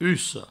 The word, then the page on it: ussa: usso